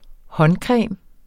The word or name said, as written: håndcreme